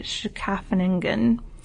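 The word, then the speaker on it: Scheveningen: So I listened to a few different pronunciations of this word, and the best I can tell, it's sort of a combination of a T, an S sound from a snake, and an SH, like Schaffeningen. But I don't think I would pass this test, unfortunately.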